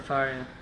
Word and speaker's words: Sefaria: Sefaria. – Sefaria.